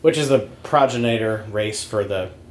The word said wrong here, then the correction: progenitor: Progenitor race. – Progenitor, yeah. I put the emphasis on the wrong syllable.